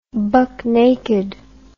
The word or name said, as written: bucknaked